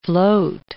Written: float

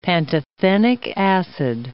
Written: pantothenic acid

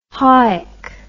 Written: hawick

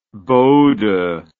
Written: Bodo

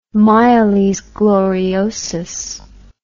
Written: miles gloriosus

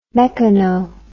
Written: mechano

